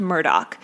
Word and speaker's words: Murdaugh: It's spelled Murdaugh, like Murdaugh, but it's pronounced Murdaugh.